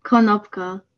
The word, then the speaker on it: Konopko: Konopko.